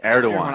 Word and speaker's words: Erdogan: Erdogan. – I always confuse how to say his name. Erdogan. Erdogan. – Erdogan. – Erdogan. – Yeah, you got it.